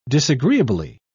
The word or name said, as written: disagreeably